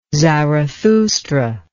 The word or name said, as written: zarathustra